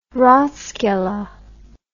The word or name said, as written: ratskeller